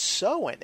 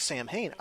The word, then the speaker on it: Samhain: the correct way to pronounce that is Sowen. It's not Samhain.